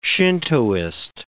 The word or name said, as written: Shintoist